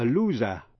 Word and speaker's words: loser: Và động từ to lose, L-O-S-E, động từ bất quy tắc. To lose, lost, lost là mất. A loser, chữ S đọc là Z, kẻ thất bại.